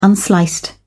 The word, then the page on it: unsliced: British English